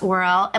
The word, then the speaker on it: Worrell: We are hosting Tess Worrell.